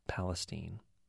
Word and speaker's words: Palestine: Spelled just like Palestine, but pronounced Palestine.